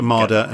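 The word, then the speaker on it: Woltemade: that it is pronounced as a W, and it's a V for Valtamada. So yeah, we need to try. – So that's Valtamada and Wissa.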